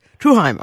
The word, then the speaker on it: Truesheim: It's a Heim name, like Sternheim, so it's Truesheim. – Truesheim, okay.